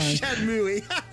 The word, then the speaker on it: Shenmue: After I let you pronounce Shenmue wrong this entire time. – Shenmue.